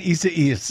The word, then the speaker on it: Isaias: Hurricane, how do I say this, Desi Doyen? Isaias. I see what you're doing. You're laughing at me. Hurricane Isaias. – There you go.